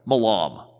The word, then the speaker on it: Milam: Remember Milam or Milam? I don't know how to pronounce his last name. Well, I need to pick a way. Milam. There it is. That's how it's pronounced now.